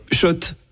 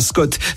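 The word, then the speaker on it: Schott: Oui, Shot et pas Scott. Ça fait des années qu'on prononce mal la marque.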